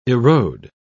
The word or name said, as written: erode